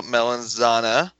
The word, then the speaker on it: Melanzana: Shout out Melanzana. Yep, that's how you say it.